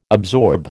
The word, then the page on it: absorb: American English